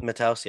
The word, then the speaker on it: Matheus: Matheus, yep.